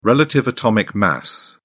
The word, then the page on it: relative atomic mass: British English